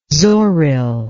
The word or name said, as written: zoril